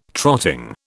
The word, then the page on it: trotting: British English